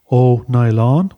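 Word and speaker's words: Ó Nialláin: This anglicisation took place from the late 17th century onwards, and by that time, O'Nylon, and I'm going to spell that for you, it's O, father, that's O with a dash over it, followed by N-I-A-L-L, A, father, I-N, O'Nylon, was found in a number of parts of the country.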